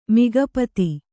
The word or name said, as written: ಮಿಗಪತಿ